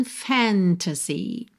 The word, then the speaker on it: fantasy: Ophelia lived in fantasy. Normalmente, a palavra fantasy tem a sílaba tônica na primeira sílaba. Fantasy.